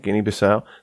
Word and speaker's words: Guinea-Bissau: Guinea-Bissau. Am I pronouncing that right? – I think so. – Okay, Guinea-Bissau.